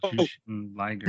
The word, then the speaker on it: Jushin Liger: And I always mispronounce his name. Jaren Lager. – Oh, you're talking about Jerry Lynn? – No, he's talking about Jushin Liger.